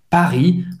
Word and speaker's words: Paris: Paris, hein, o R, acho que ela pronunciam bem o R, o R é bem gutural, Paris, Paris. É discreto, mas dá para ouvir essa, esse gargarejo.